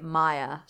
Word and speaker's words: Mia: Oh, also, the back of the box says Mia. – Yeah, it says Mia on the back. – It keeps saying Mia, like M-I-A, but they keep pronouncing it Maya.